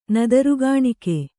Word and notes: ನದರುಗಾಣಿಕೆ: kannada

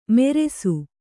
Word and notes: ಮೆರೆಸು: meresu

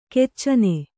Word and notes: ಖಚನ: kannada

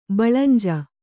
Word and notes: ಬಳಂಜು: baḷanju; Kannada

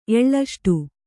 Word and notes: ಎಳ್ಳಷ್ಟು: Kannada

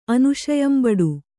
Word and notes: ಅನುಶಯಂಬಡು: anuśayambaḍu

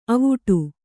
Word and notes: ಅವಟೆ: Kannada

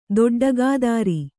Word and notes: ದೊಡ್ಡಗಾದಾರಿ: kannada

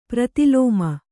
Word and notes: ಪ್ರತಿಲೋಮ: prati lōma

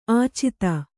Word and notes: ಆಚದು: Kannada

